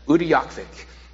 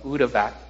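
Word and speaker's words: Utqiaġvik: I think I've said that right. I tried to write that out phonetically for myself. Utyakvik.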